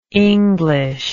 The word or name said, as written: englishment